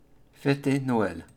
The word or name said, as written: Feter Noel